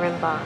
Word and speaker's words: Rimbaud: Especially not a guy who reads Baudelaire and Rimbaud. – Rimbaud? – A girl who goes out of her way to find Balzac's house. – Let's go. – Yeah, it doesn't rhyme with Limbaugh, Shannon Doherty.